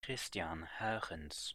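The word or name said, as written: Christiaan Huygens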